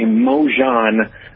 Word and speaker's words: Mongione: It's actually Mojan, like Mojan mustard.